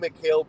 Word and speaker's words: Mikal: Mikal, you said it right. I think Mikal Bridges on a mediocre playoff team, he's a number two, but on a championship team, he's a three or a four option.